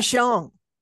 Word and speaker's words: Mahjong: Mahjong. – Mahjong with my usual group and there was a new lady there.